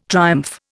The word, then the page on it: triumph: Indian